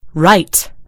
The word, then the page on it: right: British English